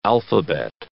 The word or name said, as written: alphabet